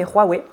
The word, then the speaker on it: Huawei: Euh, oui, on, prononce Huawei, je crois, en, France, mais voilà, donc pour ceux qui nous écoutent, Huawei ne se prononce pas Huawei, mais Huawei. Le H se prononce comme un R.